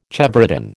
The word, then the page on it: chevrotain: American English